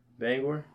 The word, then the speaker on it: Bangor: I don't know if any of those people that have been out to the Waterfront Pavilion, am I saying that correctly? Bangor? – Yeah. Is that correct? I believe that is correct.